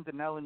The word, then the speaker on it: Dunellen: Now that's Dunellen, right? New Jersey. Am I saying that right? – That's in Dunellen, New Jersey.